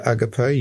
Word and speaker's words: agape: agape. Is it agape? You would know better than I would. – That's how I would pronounce it.